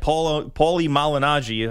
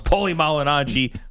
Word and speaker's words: Paulie Malignaggi: Coming up next, more with Stephen Espinoza, including what really happened with that Paulie Malignaggi, I could say that right for once, Paulie Malignaggi footage.